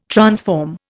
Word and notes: transform: Indian

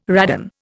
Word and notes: radon: Indian

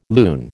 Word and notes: loon: American English

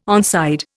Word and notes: onside: Indian

